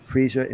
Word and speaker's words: Freesia: "My dog Freesia, pronounced Freesia like the flower, was a beautiful golden Labrador retriever with the softest ears and sweetest face.